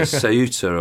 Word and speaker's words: Ceuta: It's uh, Ceuta or C E U U T A,